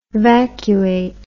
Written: vacuate

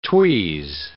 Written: tweeze

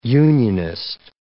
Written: unionist

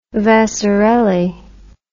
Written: Vasarely

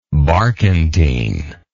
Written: barquentine

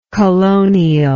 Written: colonially

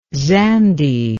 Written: zande

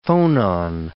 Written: phonon

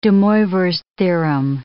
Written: DeMoivre's theorem

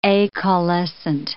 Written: acaulescent

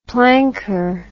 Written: planker

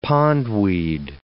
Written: pondweed

